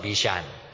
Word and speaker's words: Bashan: many of these cows of Bashan, okay, I nearly mispronounced it, cows of Bashan.